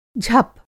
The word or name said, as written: झप